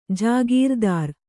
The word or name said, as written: ಜಾಗೀರ್ದಾರ್